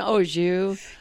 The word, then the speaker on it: au jus: And it was soaked in au jus. – Yeah. – That's how you say it. Au jus, whatever.